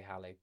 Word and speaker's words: Halley: Halley's Comet, discovered by a geezer called Edmund Halley, or Halley. The Americans say Halley, but I prefer Halley.